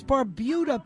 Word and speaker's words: Barbuda: Yes, that's Barbuda, people. Every time you hear it pronounced wrong, go correct somebody.